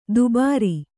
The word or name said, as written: ದುಬಾರಿ